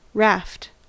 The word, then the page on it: raft: American English